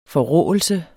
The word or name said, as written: forråelse